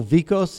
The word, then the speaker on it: Vicos: And my name is Dale Vicos, and it's spelled V as in Victor, I-C-O-S.